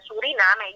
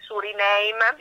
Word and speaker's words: Suriname: Suriname. Io lo dico con l'accento italiano perché insomma è un paese del Sud America, quindi con l'accento inglese Suriname mi sembra un po' troppo colonialista come approccio.